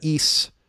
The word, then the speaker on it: Ys: I mentioned here that East, and that's it's pronounced East, but or Ease, but it's YS for people that don't know YS.